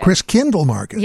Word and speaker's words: Christkindlmarket: Did I say that correctly? – Christkindlmarket. – Christkindlmarket. – Yes.